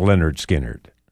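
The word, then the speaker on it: Lynyrd Skynyrd: Number 10, Leonard Skinner, pronounced Leonard Skinner.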